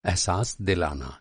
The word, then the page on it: एहसास: اِحساس